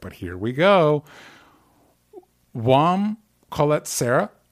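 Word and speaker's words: Jaume Collet-Serra: Yeah, I think it's Juan Colette Serra. – Thank you. Yes, because I did look it up and I found at least three different pronunciations.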